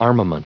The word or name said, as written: armament